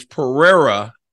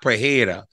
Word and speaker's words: Pereira: I keep saying that wrong. Like, how long will it take me to recognize that it's Pereira and not Pereira? Because a lot of Brazilians call the R, the H, and eh, the whole uh, confusing thing.